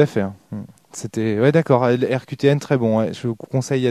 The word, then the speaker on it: RQTN: Donc ça s'écrit RQTN, comme les lettres, voilà. RQTN.